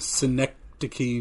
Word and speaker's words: Schenectady: Schenectady, New York.